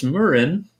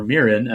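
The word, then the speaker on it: Muren: But our visual effects supervisor is Dennis Murin, or Mirin, as I hear it pronounced, just became most famous, of course, for the visual effects on the Star Wars movies.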